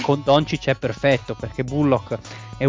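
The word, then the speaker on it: Dončić: perché ho guardato un video dove era uno del, di Afragola che diceva Doncic, quindi la pronuncia corretta è Doncic.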